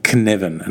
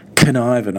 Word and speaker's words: Cynefin: So Kinevin, I think, or Kinevin is maybe the way to pronounce it.